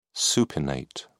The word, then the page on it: supinate: British English